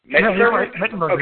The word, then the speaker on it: Mettenberger: I mean, between Ryan Fitzpatrick and Zach Mettenberger, is that even how you say it? – Mettenberger. – Okay, I wasn't sure.